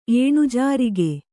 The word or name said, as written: ಏನುಜಾರಿಗ